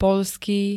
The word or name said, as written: poľský